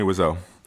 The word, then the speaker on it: Wiseau: Tommy Wiseau.